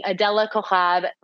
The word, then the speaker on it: Adela Cojab: Adela Cojab.